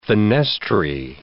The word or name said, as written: fenestrae